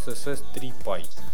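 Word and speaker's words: CSS3 PIE: А, а, для IE есть э, отличная штука, называется CSS3Py. А, PIE пишется. – Ну, как пирог по-английски. Вот.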